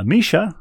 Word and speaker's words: Misia: Misha, who helpfully includes a pronunciation guide, is our next correspondent. Her name is spelled M-I-S-I-A, but she parenthetically writes Misha.